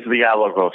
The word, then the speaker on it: Dialogos: Well, the Greek way is Dialogos.